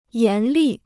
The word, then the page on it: 严厉: yán lì